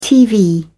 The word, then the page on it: tv: British English